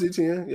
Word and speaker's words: Etienne: I don't know how to pronounce ETA. I think it's how ETN, you pronounce his name. Travis Etienne, yeah.